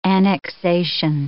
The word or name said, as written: annexation